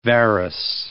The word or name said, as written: varus